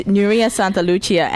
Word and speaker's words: Nuria Santalucia: The British Rising Star champions Nuria Santalucia and Mirko Scholin from Melbourne. – Thank you. – Is that right? – Yeah, yes. You said it right.